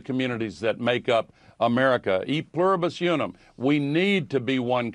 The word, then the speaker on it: e pluribus unum: And then I believe it's pronounced e pluribus unum, but I didn't go to Harvard.